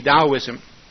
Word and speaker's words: Taoism: Taoism, it's spelled with a T, but it's pronounced with a D, Taoism.